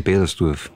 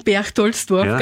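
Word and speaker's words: Perchtoldsdorf: Ja, in Bedersdorf. – In Bedersdorf, wie es heißt, in Berchtoldsdorf ganz ausgesprochen.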